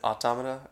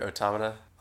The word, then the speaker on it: Automata: Is it Automata or Automata? – I say Automata because it's got like the A-U.